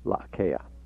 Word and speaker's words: La'akea: And the second word is K-E-A, La'akea.